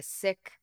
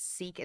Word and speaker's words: Sikh: I'm a Sikh. It often is pronounced as Sikh and Sikhism. That is incorrect. The pronunciation, we're decolonizing language. It's actually Sikh and Sikhism or Sikhi.